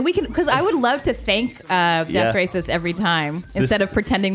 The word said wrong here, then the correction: Das Racist: This song is by Das Racist. – Das Racist. – I always say it wrong.